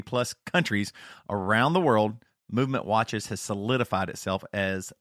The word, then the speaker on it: MVMT: MVMT watches, and it's pronounced movement, was founded on the belief that style shouldn't break the bank.